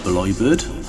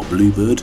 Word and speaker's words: Bleubird: Track 6 was G's on the TGVs by Bleubird, Bleubird, from Florida, but it's spelled B-L-E-U as opposed to U-E.